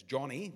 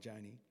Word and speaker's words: Joni: Some of you will remember Joni Eareckson, as we pronounce it in Australia the proper pronunciation is Johnny, Johnny Eareckson. Uh, if she's talking about herself, she calls herself Johnny, not Joni.